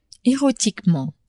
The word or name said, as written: erotiquement